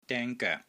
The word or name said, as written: DENG1 GOEK3